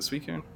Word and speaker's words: Suicune: Suicune.